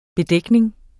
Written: bedækning

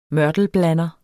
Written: mørtelblander

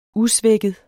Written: usvækket

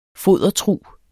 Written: fodertrug

